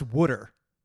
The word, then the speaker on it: water: is pronounced water. They literally, W-O-O-D-E-R is how they pronounce the word water, water.